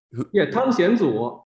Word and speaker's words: Tang Xianzu: I understand this is based on a play by Tang Xianzu, if I'm saying the name correctly. – Tang Xianzu.